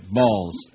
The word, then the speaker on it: Bals: German Watch Policy Director Christopher Balls said. B-A-L-S. How would you say it? Balls.